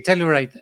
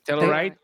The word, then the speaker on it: Telluride: Sí, Telluride. – Telluride.